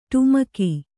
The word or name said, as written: ಡಿಮಕಿ